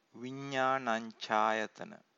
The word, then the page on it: vinnanancayatana: Pali